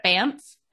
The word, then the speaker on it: Banff: but I want to go to Banff.